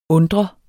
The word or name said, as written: undre